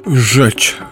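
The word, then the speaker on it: rzecz: И польское слово «жечь», что-то вроде «дело», «вещь», «сущность». Это так и произносится, а на письме начинается с буквы «Р».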